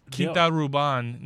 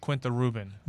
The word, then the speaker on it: Quinta Ruban: I also learned that it's Quinta Ruban, not Quinta Ruben.